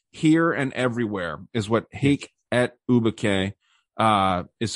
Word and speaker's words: hic et ubique: And then you have we looked up how to pronounce this before, but I already forgot. Hic et ubique. – I think you nailed it.